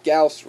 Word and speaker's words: Gauss: I love hearing Lio or Gauss rifle, as I know it should be Lao or Gauss rifle. Rhymes with mouse.